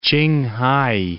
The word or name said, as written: Ch'ing-hai